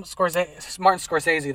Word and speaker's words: Scorsese: But I feel it's very Martin Scorsese, that's how you say it, right? – Yeah.